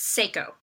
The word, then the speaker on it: Seiko: Is this pronounced Seiko Designs? – It's Seiko. – Seiko.